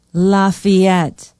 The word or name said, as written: lafayette